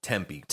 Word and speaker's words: Tempe: Were you in Tempe? Temp? – Nope. – Tempe? – Tempe? – Tempe? How do you say that? – Tempe. – Tempe? – Yeah. Tempe.